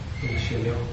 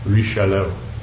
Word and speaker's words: Richelieu: Richelieu. – Richelieu.